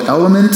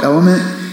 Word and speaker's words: LMNT: I just started this hydration supplement called Element. It's spelled L-M-N-T. It's called Element.